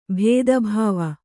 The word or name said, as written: ಭೇದಭಾವ